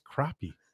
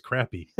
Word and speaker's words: crappie: He goes, how do you say this word? You know, like, that's crappie. He's like, good, because I thought it was crappy.